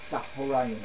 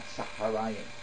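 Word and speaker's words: Tzohorayim: Whether you're Ashkenazi, Sephard, it doesn't make a difference what community, which is why we say Tzahorayim and not Tzaharayim.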